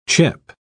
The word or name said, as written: chip